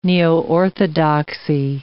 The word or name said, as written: neoorthodoxy